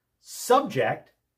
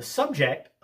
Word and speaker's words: subject: The subject of today's lesson is syllable stress. Subject with the accent on the second syllable. Subject is a verb meaning to impose something or make someone endure something.